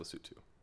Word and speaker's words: Lesotho: It is pronounced Lesotho. – Oh, excuse me. – Anyways, Lesotho.